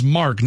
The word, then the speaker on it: Mark: I know, but his name is Mark, not Mock. – Mock. – Mark. – Mock Ewens. – There's an R in there. – I know, but Mock. – Okay, pronounce the R, please.